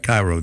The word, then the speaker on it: Cairo: So, yeah, Cairo, spelled like Cairo, the Egyptian capital, but it's said by the locals to Cairo.